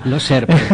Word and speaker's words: Lozère: Lozère.